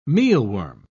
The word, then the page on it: mealworm: British English